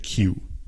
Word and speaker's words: queue: But anyway, the word queue is spelled Q-U-E-U-E. Es como que-ue, que-ue. But anyway, we say it Q in English.